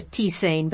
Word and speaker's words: tisane: Oh, is T-I-S-A-N? – E. – E. Tisan. I always thought it was a tisane, but that's a Midwestern pronunciation of it. – Well, I've always called it tisane, which is also fairly common. – Okay. – It's kind of that tomato-tomato thing.